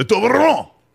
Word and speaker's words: Toblerone: I mean, if you're not classy, it's Toblerone. And then it's a silent E, the Toblerone.